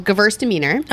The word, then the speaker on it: Gewürztraminer: How would you say it? – So this is actually like a German style wine. It's called Gewürztraminer.